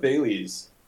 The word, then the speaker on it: Thales: First of all, how do you pronounce the name of this protocol? Is it Thales? Is it Thales? – Yeah, we're going with Thales.